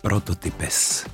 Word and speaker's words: Prototypes: Dobre som to vyslovil áno, anglicky? Áno, prototy- ví, víš proč? Nevím proč, řekni. Prototypes.